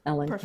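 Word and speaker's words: Elin: It's E-L-I-N. – Yes. – So because it's spelled funny, I'm pretty easy to find. Just Ellen Kelsey, but spelled funny.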